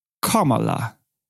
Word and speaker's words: Kamala: Kamala. Es wird auf der ersten Silbe betont Und sie selbst sagt, es wird ausgesprochen wie das englische Wort für Komma, Komma und La.